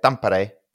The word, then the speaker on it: Tampere: I guess I would butcher it. But that's how they say it, Tampere.